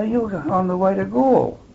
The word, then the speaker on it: Galle: Now, Gaul is spelled G-A-L-L-E, and it's a fairly nondescript little town at the very southern tip of Ceylon.